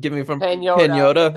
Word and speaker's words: Pagnotta: Yeah, and I saw, I think it was David Pagnotta. I tweeted it out this morning too. Pagnotta, I don't know, from Pagnotta. – That's how you pronounce it.